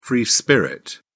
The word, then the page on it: free spirit: British English